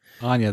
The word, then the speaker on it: Anya: Anya, there you go.